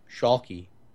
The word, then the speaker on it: Schalke: It's Schalke, and I don't know how it's supposed to be pronounced. Schalke? – Say Schalke? – It with a German accent. – Schalke. – Okay, that's probably it then.